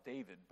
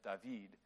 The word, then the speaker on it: David: It's spelt David, but it's pronounced in French David.